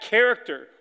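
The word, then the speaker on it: character (χαρακτήρ): The Greek word is actually, character. That's actually how it's spelled. It's how it's said. It's kind of cool. He is the exact character of God.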